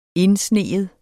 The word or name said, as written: indsnige